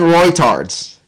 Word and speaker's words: Reuters: Yeah, it's pronounced, well, it's spelled R-E-U-T-E-R-S, but it's pronounced Reuters, and some people pronounce it without the U.